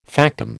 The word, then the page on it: factum: American English